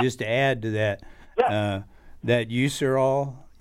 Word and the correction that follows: Urushiol: how do you pronounce that? – Urushiol.